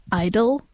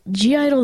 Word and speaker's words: (G)I-DLE: The first thing that I want to say is, like, a lot of people just say I-DLE, which I think is wrong because, well, G-Idle themselves have said G-Idle.